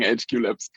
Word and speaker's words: HQ Labs: Habe ich das jetzt richtig ausgesprochen? HQ Labs? – Wir sagen HQ Labs, genau. HQ Labs, okay.